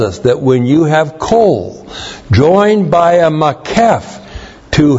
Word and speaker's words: kol: And then the noun kol, kol, K-O-L, means all or the whole of, literally.